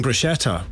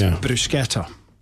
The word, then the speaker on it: bruschetta: CH in Italian is pronounced K. So when we're saying bruschetta, we're getting it wrong every time. It's bruschetta.